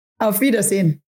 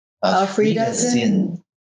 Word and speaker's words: Auf Wiedersehen: Auf Wiedersehen. – Auf Wiedersehen.